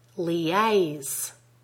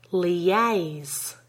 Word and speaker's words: liaise: Pay careful attention to the word stress in the following words. Liaise. Can you hear that we link the two syllables in that word with a weak y sound? Have a listen. I'll say the word three times. Liaise. Liaise. Liaise. The stress is firmly on the second syllable.